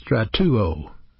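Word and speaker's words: strateuo: It's pronounced stratuo, stratuo.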